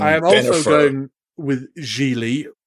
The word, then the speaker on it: Gigli: It is pronounced Gigli, rhyming with really.